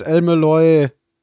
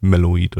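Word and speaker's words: El-Melloi: Äh, die meisten Fans sagen einfach Lord El Meloy oder einfach nur El Meloy, ah. aber ich glaube ja, El Meloy, der zweite ist es auf jeden Fall, also The Second wird schon richtig sein. – Okay. Meloy, nicht Meloid.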